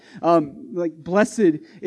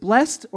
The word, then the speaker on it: blessed: Is it blessed or blessed? It's blessed when it's an adjective. It's blessed when it's a verb.